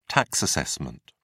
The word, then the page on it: tax assessment: British English